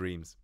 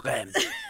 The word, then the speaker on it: Reims: And she was like, oh, you probably know it as Reims, but it's pronounced Rennes.